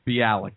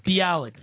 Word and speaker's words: Bialik: That's Mayim Bialik, I think, is how you say that. – Bialik. – Bialik. Thank you.